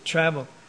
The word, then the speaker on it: travel: The accent is on the first syllable, travel.